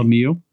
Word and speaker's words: Lemieux: Was it Lemoy? – Lemieux, because it's sort of Canadian, even though he's American.